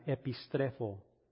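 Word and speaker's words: epistrefo (ἐπιστρέφω): Se pronuncia epistrefo.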